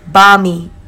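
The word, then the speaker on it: bánh mì: And I really liked bung mi. Australians would pronounce it bar mi.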